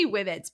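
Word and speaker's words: Wibit: Of course, they have the Whibbits, although I don't know if I say that right. I think it's Whibbits.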